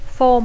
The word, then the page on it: form: Catalan speaker; intermed